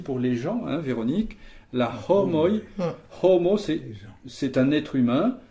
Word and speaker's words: homoj: Et pour le H aspiré, la homoi.